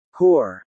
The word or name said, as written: Corps